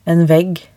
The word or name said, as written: en vegg